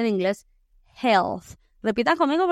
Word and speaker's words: health: Health, health. Se escribe H-E-A-L-T-H. E Lo que pasa es que la A es silenciosa aquí. Entonces decimos health.